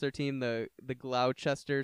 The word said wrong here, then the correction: Gloucester: Stripers. – Yeah. – But he's like, dude, it's Gloucester. – Yeah, that didn't make any sense. The spelling behind that.